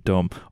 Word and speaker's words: dumb: There's a B at the end. In case you spell it D-U-M, that's just clarifying for the dumbest among us. – Don't forget the silent B. – There is a silent B in dumb.